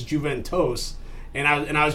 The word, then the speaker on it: Juventus: I know it's a J-U, but even if it was Jew, it's pronounced kind of both ways. It's primarily Juventus. That's how most people say it. But I've heard it the other way too, where it's like tomato, tomato, Juventus, Juventus. But it's Juventus.